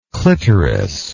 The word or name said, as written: clitoridean